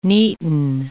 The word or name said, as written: neaten